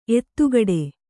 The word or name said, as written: ಎದೆಗೂಡು